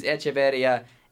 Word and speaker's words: Echevarria: It was Echevarria, and the pronunciation is confirmed, by the way. E C H E V A R R I A.